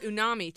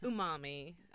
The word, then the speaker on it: umami: It's unami too. – Oh, unami. I love unami. – Umami.